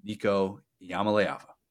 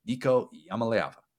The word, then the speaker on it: Nico Iamaleava: I'm very, very bullish on the man, the myth, the legend, Nico Iamaleava. We're going to say it over and over until everybody who listens to this show knows how to say his last name, Nico Iamaleava.